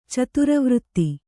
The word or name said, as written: ಚಿತ್ರವರ್ತ